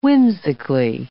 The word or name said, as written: whimsically